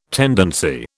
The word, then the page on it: tendency: British English